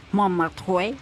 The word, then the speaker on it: Montmartre: Pero el domingo pasamos por Montmartre, perdón mi pronunciación del francés, ya sé que así no se pronuncia. – Montmartre.